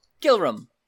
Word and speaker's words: Gillrum: S-E-I-R-B-O-C. Sirbok. Gillrum. G-I-L-L-R-U-M.